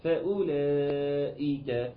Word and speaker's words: فأولئك: الكلمه دي مفيش فيها واو اصلا، فاكر كلكم ايه؟ كلهم بيقولوا ليس فيها واو اصلا. انما طيب احنا شايفين واو مرسومه، دي مرسومه ولكنها لا تنطق. عارف لو اتنطقت كنت اقول ايه؟ فاولئك فاولا، انا ما بعملش كده، امال ايه؟ بنتقل من الهمزه المضمومه لام المفتوحه على طول فاولا فأولئك، يبقى ناخد بالنا منها، ودي برضو دخل معانا بس الوصف اللي احنا لازم نتمكن منه كويس جدا جدا.